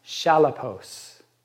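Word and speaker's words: chalepos: Well, we know what it means when we read it, but perilous, the Greek word for perilous, I think it's pronounced shalapos, and it's only used twice in all of the Greek part of the Bible, in all of the New Testament.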